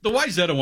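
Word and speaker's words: Wayzata: The Wyzetta one drives me nuts because there's no reason that should be pronounced that way when you look at how it's spelled.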